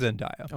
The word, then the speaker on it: Zendaya: But I think Zendaya, is that how you pronounce her name? – I believe it is Zendaya.